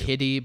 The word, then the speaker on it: Kiri: Is Kitty, but it's spelled K-I-R-I. So that was really confusing to me. Mm. When I looked up later, I was like, is her name Kitty? Because it sounds like Kitty.